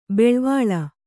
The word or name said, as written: ಬಳ್ವಳ